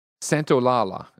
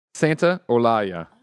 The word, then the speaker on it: Santolaya: I did a check. Santolaya. Here, spelled phonetically. Santa Olaya.